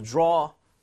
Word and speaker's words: draw: O 하면 안 돼요. 이거 약간 벌려줘야 돼요. – 어떻게 할 거예요? – Draw. Draw. – Just like that. 여러분, AWAU는 이 모양을 많이 벌려주세요.